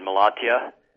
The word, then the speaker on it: Malatya: By the way, am I pronouncing Malatya correctly? – That's correct, yes. That's very good, Malatya.